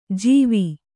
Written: ಜವಿ

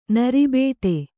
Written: ನರಿಬೇಟೆ

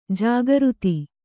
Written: ಜಾಗೃತ

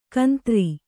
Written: ಕುಂದ್ರಿ

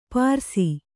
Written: ಪಾರಸಿ

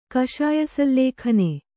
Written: ಕಷಾಯಸಲ್ಲೇಖನೆ

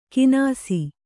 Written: ಕಿನಾಸಿ

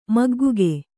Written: ಮಗ್ಗುಗೆ

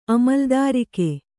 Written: ಅಮಲುದಾರಿಕೆ

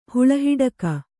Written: ಹುಳಹಿಡಕ

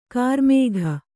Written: ಕರಿಮುಖ

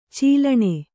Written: ಚೀಲಣ

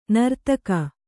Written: ನೀರ್ತೆಗೆ

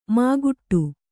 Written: ಮಾಗುಟ್ಟು

